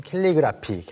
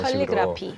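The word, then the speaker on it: calligraphy: 이제 한국어로는 캘리그라피, 캘리그래피 이런 칼리그라피. – 식으로 캘리로 하는데 영어로는 클리로 발음이 시작되죠.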